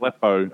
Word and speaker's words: Aleppo: in Alpaco, Syria. – Yeah, right. – What's it called? How do you spell that? – Aleppo. A-L-E-P-P-O. – A-L-E-P-P-O.